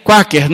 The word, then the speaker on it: Quaker: Alguns até falam aquela aveia quaker, né? Mas a aveia quaker, no inglês a gente pronuncia quaker.